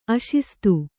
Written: ಅಶಿಸ್ತು

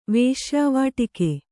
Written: ವೇಶ್ಯಾವಾಟಿಕೆ

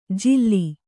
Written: ಜಿಲ್ಲಿ

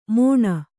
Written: ಮೊಣ

